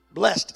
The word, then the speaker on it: blessed: Blessed, and I know most people pronounce this blessed. That's the old English pronunciation. It really is just blessed. That's what the word means. It's blessed. Blessed are ye when men shall hate you,